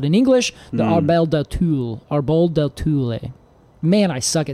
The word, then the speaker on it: Árbol: Like, well, it potentially is because the accent is on the first syllable. Árbol.